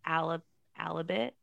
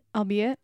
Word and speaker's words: albeit: Alibit. How do you say that word? Alibit. – Albeit.